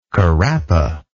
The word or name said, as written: carapa